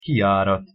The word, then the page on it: kijarat: Hungarian